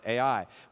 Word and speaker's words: Ai: It's spelled Ai, and it's pronounced Ai.